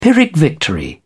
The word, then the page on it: pyrrhic victory: British English